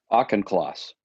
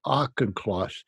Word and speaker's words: Auchincloss: Auchincloss. – Auchincloss.